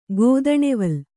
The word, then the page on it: ಗೋದಣೆವಲ್: Kannada